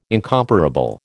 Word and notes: incomparable: American English

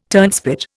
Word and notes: turnspit: Indian

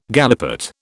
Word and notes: galipot: British English

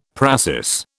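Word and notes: précis: British English

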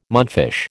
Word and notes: mudfish: American English

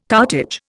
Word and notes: cartage: Indian

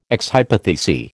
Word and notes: ex hypothesi: American English

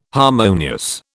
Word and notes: harmonious: British English

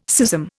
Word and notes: schism: Indian

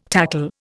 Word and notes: tackle: Indian

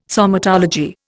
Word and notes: somatology: Indian

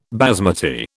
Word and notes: basmati: British English

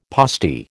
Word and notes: postie: American English

